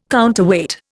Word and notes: counterweight: Indian

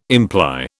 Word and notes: imply: British English

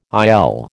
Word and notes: IL: American English

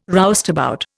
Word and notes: roustabout: Indian

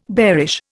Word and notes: bearish: Indian

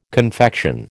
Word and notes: confection: American English

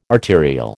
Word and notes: arterial: American English

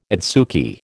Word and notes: adsuki: American English

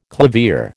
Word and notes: clavier: American English

